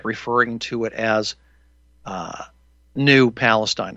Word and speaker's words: Palestine: And it's only because I was trying to correct somebody who kept mispronouncing Palestine. They kept saying Palestine, and it's Palestine.